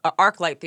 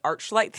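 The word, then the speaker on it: ArcLight: and he mispronounced arc light theaters. He called it arch light theaters.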